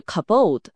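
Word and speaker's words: kabod (כבוד): 在旧约的希伯来语中，荣耀这个词发音是 kabod，它的词源意义其实是重量或者沉重。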